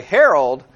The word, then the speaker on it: herald: And by the way, herald rhymes with Gerald, if that helps anybody remember it.